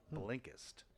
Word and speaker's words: Blinkist: B-L-I-N-K-E-S-T, Blinkist. – Blinkist. – Yeah. Have you really not? Oh, it's Blinkist. It's I-S-T. – I-S-T.